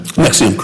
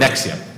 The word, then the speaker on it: NXIVM: I'm saying the fact of her doubling down on Raniere, of her hurting folks who chose to criticize not DOS, but Nevexium. I never know how to pronounce it. But Nexium, correct. – Nexium.